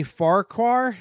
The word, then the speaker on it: Farquhar: Let's talk about Danny Farquhar. Am I saying that correctly? – I think so.